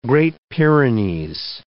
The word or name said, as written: Great Pyrenees